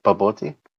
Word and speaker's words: bobotie: Bobotai? – Bobotie. – Wait, was that a word? Did I say it right? Bobotie? – Bobotie, yes.